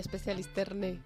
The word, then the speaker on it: Specialisterne: Bueno, es facilito porque realmente supongo que en noruego se pronuncia, en danés se pronunciará de otra manera, pero aquí lo españolizamos. – Sí, sí, Specialisterne.